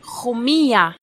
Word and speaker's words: Jumilla: Jumilla, how do you pronounce it? Jumilla. – Jumilla. – Yeah, but it's J-U-M-I-L-L-A. – Yes. Double L-I, yes. – Yeah, I always think of it as Jumilla, but Jumilla.